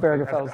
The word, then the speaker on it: Obergefell: Obergefell, right? – It's tough. Obergefell. Hard G, I think.